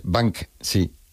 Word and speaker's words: Banksy: Y se pronuncia, en España la gente lo dice de otra manera, ¿no? Pero bueno, como Banksy. – Esas palabras. Es Banksy.